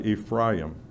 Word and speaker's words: Ephraim: And we pronounce this Ephraim, but it's really Ephraim. It means fruitful or double fruit.